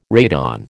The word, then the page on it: radon: American English